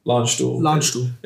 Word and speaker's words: Landstuhl: Am I saying that right? – Landstuhl. It's all right. You put the American twang on it.